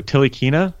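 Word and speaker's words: Ntilikina: Do I pronounce the N or do I not pronounce the N? – I don't think you do. Tilikina? – Tilikina. Yeah. Okay.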